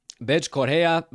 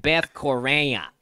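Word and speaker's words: Bethe Correia: That is precisely how you say it. That is Brazilian. If you don't believe me, that would be, if you're a nice nasally Chicagoan, it would be Carol Rosa and Beth Correa.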